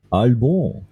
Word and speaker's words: Albon: Ähm, in, du hast den Gefühl doch viel zu richtig ausgesprochen. – Ja, Albon. Wahrscheinlich heißt er Albon.